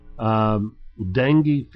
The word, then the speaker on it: dengue: Dengue, D-E-N-G-U-E. – It's not like your little dinghy? – No.